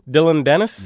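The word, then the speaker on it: Dillon Danis: His training partner, how do you say this, Dylan Dennis? – Yes, that's how you say it.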